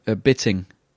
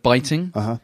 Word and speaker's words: biting: Well, like a classic example, the other day, my wife said the word bitting instead of biting. I mean, to be fair, it's spelt B-I-T-I-N-G. So why would the two I's be pronounced differently?